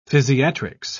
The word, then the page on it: physiatrics: British English